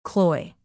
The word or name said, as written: cloy